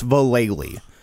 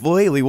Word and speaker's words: Vallely: And, like, she'd call a skate shop and be like, yeah, how many Mike Valleys do you want? He's like, ma'am, if you're not going to say the name right, I'm not going to buy it off you. It's Vallely.